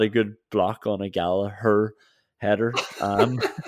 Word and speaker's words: Gallagher: You said Matip and uh, I hear a lot of commentators and English people say Gallagher. I have to tell you, it's Gallagher.